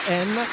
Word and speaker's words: Anna: che si chiama Enna, diciamo, in realtà si chiama Anna, però essendo brasiliana, ancora fosse stata diciamo siciliana potevo capire Enna, però non lo so, lei dice che si pronuncia Enna.